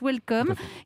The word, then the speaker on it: WLCM: Vous êtes le fondateur de Welcome, mais ça s'écrit WLCM. C'est comme ça qu'on vous reconnaît. Mais ça se prononce Welcome, qui est une application qui regroupe toutes les informations touristiques d'une destination.